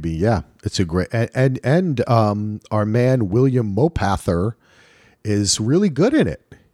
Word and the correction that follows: Mapother: Mopather. – Isn't that how you pronounce it? – Mapother. – Mapother.